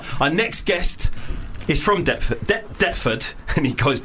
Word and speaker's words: Deptford: Deptford is a fucking annoying word because it's got a silent P in it, and I only figured that out like a week ago.